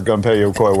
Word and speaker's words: Gunpei Yokoi: The prostitution hotels might have done better if Gunpei Yokoi worked on them.